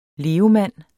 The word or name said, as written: levemand